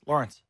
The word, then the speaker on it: Lorenz: It's actually, you say it like Jennifer Lawrence. It's Lawrence.